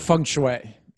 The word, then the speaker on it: feng shui: How do you say that word? – Feng shui. – Feng shui. – Yeah, feng shui.